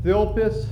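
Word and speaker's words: thilpis (θλῖψις): It's called a thilpis. That's how you pronounce it.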